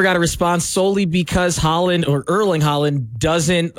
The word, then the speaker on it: Erling Haaland: Tom Holland, aka Spider-Man, apparently DM'd Erling Haaland.